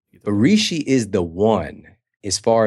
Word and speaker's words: Reishi: Reishi, is that how you say it? – Yeah. Reishi.